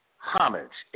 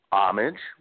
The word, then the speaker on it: homage: I got a couple of I've got a couple of pronunciation guys on my top 10, and number 7 is homage, H-O-M-A-G-E, which is increasingly mispronounced homage without the H, or worse, homage. What's with the de-anglicization?